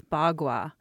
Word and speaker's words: Bagua: And how do you pronounce that? – That's Bagua. – Bagua. Okay. Yeah. What does Bagua mean? – Well, it's a Chinese word, and it means, Ba means eight, and Gua means images.